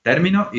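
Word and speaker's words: término: Then termino is term, the stress in the first syllable. Termino is I finished. He termino, he or she finished. So different meaning according to the position of the stress.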